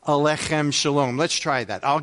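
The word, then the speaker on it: aleichem: In order to say alechem properly, you have to pretend you have a piece of matzah stuck in your throat and you're trying to get it, because the accent is on the kh in alech.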